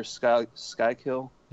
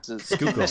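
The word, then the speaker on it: Schuylkill: I think one of the RotoWire Slack chat participants or members, Stan, I think his name is, River Skykill. – Yeah. – Skookle.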